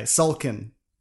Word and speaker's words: Sulkin: Okay, Sulkin. Did I say that right? – Yeah, you did.